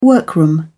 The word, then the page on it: workroom: British English